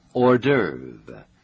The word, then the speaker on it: hors d'oeuvre: 一个特殊的名称，那就是 hors d'oeuvre. It is spelled H-O-R-S and D apostrophe O-E-U-V-R-E. It's pronounced hors d'oeuvre. – 大家一定感到这个字的拼法很奇怪。是的，这个字本身是法文，所以它的拼法和一般英文拼法不太一样。我们再请 给大家拼一次。 – Hors d'oeuvre. H-O-R-S and the last part is spelled D apostrophe O-E-U-V-R-E hors d'oeuvre.